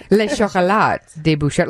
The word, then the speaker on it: Le Chocolat de Bouchard: And I was trying to pronounce Kathy's company, Le Chocolat de Bouchard. Did I say it okay? – Le Chocolat. – Le Chocolat de Bouchard, like the movie, Chocolat.